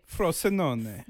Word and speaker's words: Frosinone: And then they head to, I don't know how to say, Frosinone.